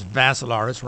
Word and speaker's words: Vassilaris: Last name is Vassilaris, rhymes with Asilaris.